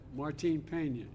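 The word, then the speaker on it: Martín Peña: Help me the pronunciation. – Martin Peña.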